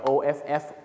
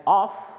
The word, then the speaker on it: off: of of กับ off off นะครับอาจจะออกเสียงไม่เหมือนกันนะฮ